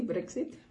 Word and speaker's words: Brexit: Cuando empezaron con todo esto del Brexit, o como se pronuncia, pastora. – Sí, Brexit.